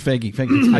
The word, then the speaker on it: Feige: That's Feige.